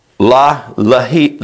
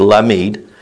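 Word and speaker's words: Lamed (למד): Lamed. That's how that glyph is pronounced. Lamed.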